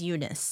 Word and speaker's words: Younes: His name is Eunice. – That's so cool. – Yeah. It's spelled, like, Younes.